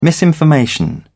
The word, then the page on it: misinformation: British English